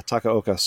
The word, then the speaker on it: Takaoka: In honor of Vancouver's Japanese keeper, Yohei Takahoma. I believe that's how you pronounce his name. – Takahoma. – Yeah. Takaoka? – Yeah. – Takaoka. Sorry. Yohei Takaoka.